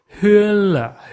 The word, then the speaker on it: Hoernle: 比如我们那个我们圈里一个很著名的德裔的英国，呃，印度学家，呃，翻译作霍霍恩雷，他的名字拼作 H O E R N L E，O E R 就是不知道怎么念，其实是 Hun 了 Hun 了那样呃，就是 O E 念呃这样，你所以所以现在这个德语德语的拼写就跟。